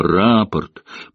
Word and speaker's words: рапорт: Слово «рапорт» прапорщик произнес с ударением на первом слоге, что в разумении Довганта являлось стратегической ошибкой.